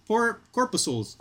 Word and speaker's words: corpuscles: How do you say that word? Corpuscles? Corpuscles. – Corpuscles. – Corpuscles. Okay, thank you.